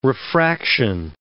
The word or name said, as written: refraction